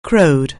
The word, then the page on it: crow: anglais